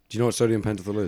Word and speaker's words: Pentothal: Two psychiatrists gave Hirons sodium pentothal. – Pentothal. – Is that how you pronounce it? – Mm-hmm.